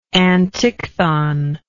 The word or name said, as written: antichthon